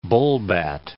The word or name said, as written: bullbat